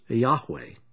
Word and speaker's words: Yahweh: Although absolute proof is lacking, a good case can be made that the letters YHWH are pronounced as Yahweh.